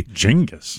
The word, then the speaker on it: Genghis: Genghis. – So there, yeah, that's correct pronunciation.